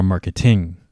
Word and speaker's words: marketing: Okay, so it's going to be the first syllable, marketing. Okay, so we don't say marketing, right? Or marketing. Okay, we say marketing.